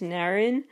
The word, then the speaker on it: Narin: I believe it's pronounced Narin. It's N-A-R-I-N.